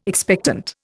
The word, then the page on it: expectant: Indian